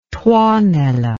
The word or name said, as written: tuonela